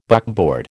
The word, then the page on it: buckboard: American English